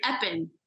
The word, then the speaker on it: Eppen: No worries, Eppen.